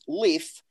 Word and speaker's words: Lreaf: Our made up son was, we would tell them, his name is Leaf, which is spelled L-R-E-A-F. The R is silent.